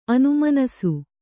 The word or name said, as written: ಅನುಮನಸು